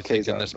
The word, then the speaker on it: Kezar: It's pronounced Kezar, but go ahead. – Now I'm fucking confused. I don't even know if I said it right. – You did.